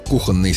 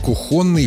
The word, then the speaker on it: кухонный: Отсюда и устойчивые сочетания – кухонный стол, кухонный гарнитур, кухонные полотенца, кухонная посуда. Но в живой речи вариант «кухонный» встречается довольно часто. Он привычен на слух и поэтому легко закрепляется, хотя нормой не считается.